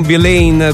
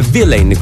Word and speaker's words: Villain: Non Villain, come molti sono convinti, perché la vera pronuncia del suo cognome è Villain, quindi l'accento sulla I.